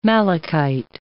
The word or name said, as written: malachite